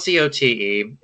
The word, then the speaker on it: Cote: Initially, it was pronounced Cote. When whichever relative moved to New England in the 20th century, made it Cody to Americanize it. Phonetically, it probably should be Coach.